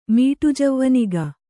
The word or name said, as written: ಮೀಟುಜವ್ವನಿಗ